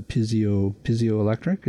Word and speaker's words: piezoelectric: So it's the piezoelectric, is that? – Yeah. – Am I pronouncing that correctly? – Yep. – Yeah. – Close enough.